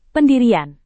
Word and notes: pendirian: Indonesian

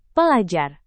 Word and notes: pelajar: Indonesian

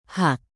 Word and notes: hak: Indonesian

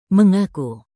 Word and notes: mengaku: indonesian

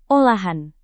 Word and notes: olahan: Indonesian

